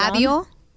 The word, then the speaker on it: Eladio: Eladio.